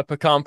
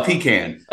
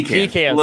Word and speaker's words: pecan: So you're a pecan pie person, huh? – Well, pecan. – Oh, pecan. Sorry. Pecan. Got to get the pronunciation right here.